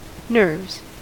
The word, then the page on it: nerves: American English